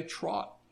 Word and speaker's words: trout: If you get someone, an old-timer from Lebanon County, to pronounce the word T-R-O-U-T, he's going to pronounce it the same way as what you would think of a horse trotting down the road. He's going to say trot.